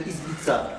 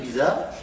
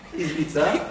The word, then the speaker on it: Izbitza: Plus connu sous le nom de Rabbi Mi-Ishbitzer, le rabbi de Izbitza, pas de Giza, de Izbitza.